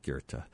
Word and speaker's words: Goethe: What was it Marx liked to quote from Goethe so often, or Goethe, I guess is the way to pronounce it, so often, G-O-E-T-H-E. I say it so you know what I'm talking about, the way it's spelled, but it is spelled Goethe. I guess I shouldn't do that. I should just say Goethe, like Wagner, right?